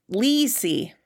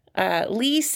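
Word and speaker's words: leesy: Lisi. Lisi. – Can you spell that? – That is spelled L-E-E.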